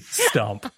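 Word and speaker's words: Stuhmp: And then I went, this is my name now, and it's S-T-U-H-M-P, Stump. – The H is silent, yeah.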